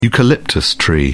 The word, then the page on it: eucalyptus: British English